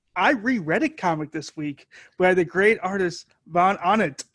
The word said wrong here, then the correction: von Ahnen: Of me just sitting there mispronouncing the author's last name. Literally just 20 minutes of me going, Von Annen. – His name is Mark Von Ahnen.